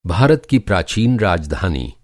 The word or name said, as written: राजधानी